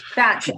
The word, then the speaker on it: Batch: Batch, as in cookies without the S.